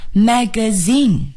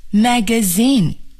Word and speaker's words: magazine: 请朋友们注意啊， magazine 它的重音呢是在第三个音节，也就是 zine 上面，那么前面呢 mag 都不要重读，我们把最重的这个读音呢要放在 zine 上，大家试一下 magazine, magazine.